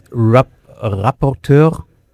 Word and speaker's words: rapporteur: You're a digital European Union ambassador, and the last one is rapporteur. Did I pronounce correctly? – Yes.